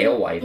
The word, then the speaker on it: Alewife: I always say alewife, because that's how they say it on the red line over the speaker.